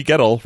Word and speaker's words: Gettle: in Huntsville, Donnie Gettle, rhymes with kettle, Curtis in Georgia, Stuart in Fort Smith, Arkansas, Joseph Perdue, Dan in Omaha, Alejandro Cardoso Solis, and Charles in Vegas.